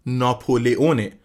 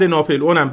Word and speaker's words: ناپلئون: تلفظ صحیح این اسم ناپلئونه، ولی برای راحتی کار و توی این پادکست از تلفظ ناپلئون استفاده میکنیم که گوشمونم بیشتر بهش عادت داره.